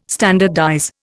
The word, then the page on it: standardize: Indian